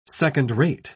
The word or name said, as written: second-rate